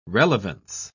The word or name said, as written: relevance